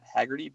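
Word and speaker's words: Heagerty: my name is Matt Haggerty, but it's spelled M-A-T, H-E-A-G-E-R-T-Y.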